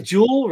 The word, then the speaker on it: Juul: Juul, right? That's how you say it.